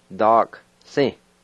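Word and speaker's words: dakisi: Da-ki-si. Da-ki-si. Now, let me point out, the first I, it can be silent when you pronounce it. So let me say it again. Da-ki-si.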